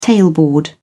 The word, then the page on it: tailboard: British English